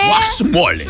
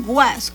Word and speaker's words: huaxmole: Es que aquí es con X, ¿no? Aquí se escribe es con... H-U-A-X-M-O-L-E. – Ok, guasmole. – Pero le dicen guas, con G, guas.